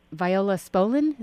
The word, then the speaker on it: Viola Spolin: I'll tell you a little backstory, how I heard about Viola Spolin.